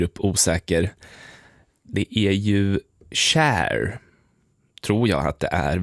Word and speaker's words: Cher: Och då står det som i engelskans shine rimmar på bear och inte på beer. Cher, alltså.